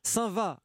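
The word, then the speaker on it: Saint-Vaast: Alors, on prononce bien Saint-Va, hein, pas d'erreur. Complètement, c'est Saint-Va. Voilà, faut pas se contenter de cette orthographe qui peut des fois prêter à confusion. C'est bien Saint-Va-la-Hougue, oui.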